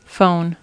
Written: phone

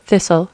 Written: thistle

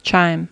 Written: chime

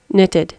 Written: knitted

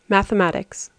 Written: mathematics

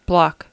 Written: block

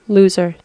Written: loser

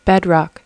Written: bedrock

